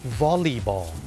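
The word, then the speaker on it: volleyball: วอลเลย์บอลไปแล้วเนี่ยคนไทยเนี่ยเมื่อพูดถึงกีฬาวอลเลย์บอลเนี่ยออกเสียงว่าวอลเลย์ถูกไหมครับวอลเลย์บอลแต่จริงๆแล้วนะครับเจ้าของภาษาเนี่ยไม่ได้ออกเสียงว่าวอลเลย์บอลนะครับเขาออกเสียงว่าวอลลีบอลวอลลีบอลตัว L E Y เป็นออกเสียงลี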